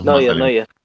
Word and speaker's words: Neuer: Entonces, también había pasado que el capitán de Alemania, el arquero Neuer, ¿cómo se pronuncia? – Sí. Neuer.